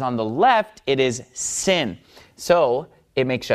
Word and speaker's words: Sin: Then it becomes pronounced sin, and it's a s sound, just like samech, all right?